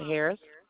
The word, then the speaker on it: Harris: Monica Harris. – And Monica is spelled M-O-N-I-C-A? – That's correct. – And Harris, H-A-R-R-I-S.